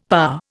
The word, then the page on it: parr: Indian